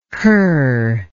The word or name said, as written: curr